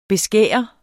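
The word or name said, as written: beskære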